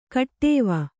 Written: ಖಟ್ವ

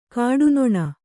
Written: ಕಟ್ಟನ್ನ

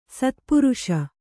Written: ಶತಪ್ರಾಸ

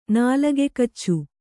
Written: ನಾಲಗೆಕಚ್ಚು

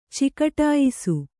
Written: ಚಿಕಟಾಯಿಸು

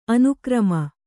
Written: ಅನುಕ್ರಮ

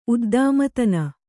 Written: ಉದ್ದಾಮತನ

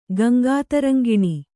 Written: ಗಂಗಾತರಂಗಿಣಿ

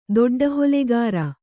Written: ದೊಡ್ಡಹೊಲೆಗಾರ

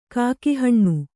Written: ಕಾಕಿಹಣ್ಣು

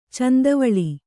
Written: ಚಂದವಳಿ

